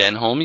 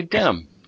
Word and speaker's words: Denholm: You don't say Denholm, you say Denham.